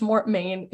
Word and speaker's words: Mortmesne: It's pronounced, in the audiobook, it's pronounced Mortmain. If you read these books, it will be spelled like Mortmesne.